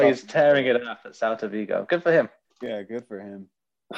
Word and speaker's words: Celta Vigo: Celta Vigo? Is that how you pronounce it? Celta Vigo? – Yeah.